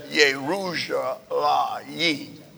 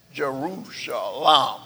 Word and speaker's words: Yerushalayim: There is no J in the Hebrew language, so there is no such thing as Jerusalem. When you can't pronounce that in the Hebrew language. And since that is the capital, it's not Jerusalem.